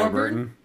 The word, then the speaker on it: Warburton: But this song, music video, features William Shatner, Ethel Harris, Patrick Waterbourbon. – Patrick Warburton.